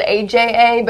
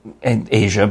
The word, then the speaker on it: Aja: Well, yeah, because I don't think a lot of people realize that Asia by Steely Dan is spelled A-J-A, because you look at it and it looks like Aja or Aja. – Exactly. – Or any number of other things.